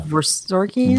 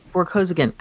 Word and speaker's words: Vorkosigan: So she's known for really more for her other series, which is, I don't know how to say the last name, Miles Vorkosigan? – Vorkosigan. – Vorkosigan. I got them transposed.